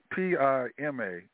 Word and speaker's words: Pima: And Primer County, Arizona. I mispronounced it. PIMA, Pima County, Arizona.